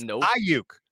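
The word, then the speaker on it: Aiyuk: It's Ayuk, dude. It's not Ayuk or Ayuk. It's Ayuk. It's not that hard.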